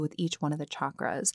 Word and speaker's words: chakra: Now, I will say right off the bat, I do pronounce them chakras, like chak or chaturanga. That's my understanding of that is the phonetic way to pronounce them instead of like chakra, because other Sanskrit terms that have the CH, like chaturanga, have the ch.